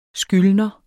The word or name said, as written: skyldner